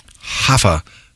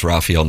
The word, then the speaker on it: Rafael: So, Doug, I've got them at 9-3-2 since that bad start. Yeah, very nice. – Navarro had a brace. – Don't pronounce it that way. – Hafa Navarro. – Yep. Doug, you look at it and you go, oh, that's Rafael Navarro.